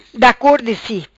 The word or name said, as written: Da cor di si